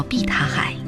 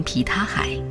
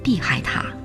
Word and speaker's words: 碧塔海: 蓝色的湖泊叫碧塔海，本地人会念成皮塔海，而外地人又会经常说成碧海塔。其实碧塔是藏语，意思是栗树成毯的地方，所以碧塔海就是像牛毛毡一样柔软的海，也就是宁静的海。